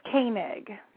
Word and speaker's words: Koenig: It's Koenig.